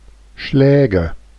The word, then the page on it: Schläge: /ˈʃlɛːɡə/